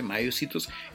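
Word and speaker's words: Maiacetus: Tenemos un animalito que se llama se llama ya así es que se pronuncia Mayocitus se escribe Mayacetus con C, Mayacetus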